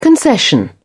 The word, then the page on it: concession: British English